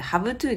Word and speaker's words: have to: この場合の have は、まあ、前ね used to っていうのもご紹介したんですけども、濁らせずに発音します。なので have to ではないのでご注意ください。